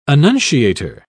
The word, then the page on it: annunciator: British English